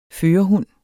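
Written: førerhund

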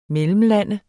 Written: mellemlande

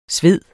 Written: sved

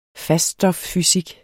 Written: faststoffysik

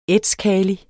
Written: ætskali

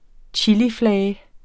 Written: chiliflage

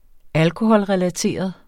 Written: alkoholrelateret

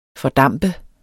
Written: fordampe

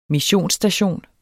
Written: missionsstation

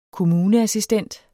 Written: kommuneassistent

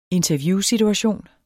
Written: interviewsituation

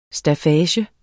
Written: staffage

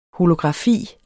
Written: holografi

